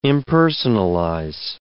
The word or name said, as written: impersonalize